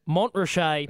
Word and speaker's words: Mont Rocher: Phonetically, I guess you could say it is Mont Ratchet. Race number 9, horse number 10, Mont Rocher.